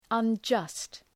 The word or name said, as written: unjust